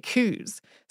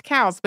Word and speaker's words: Coues: Which is named after Elliot Coues, but most people say it coos. So if you know about these deer and you think we've been saying his name wrong this whole time, uh, the deer are technically also pronounced cows, but nobody really says it that way.